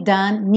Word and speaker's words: done: Então, when the day is nearly done, nearly done. Ok? Agora o done, uma sílaba só, done. Esquece esse E final.